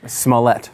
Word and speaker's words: Smollett: Smollett.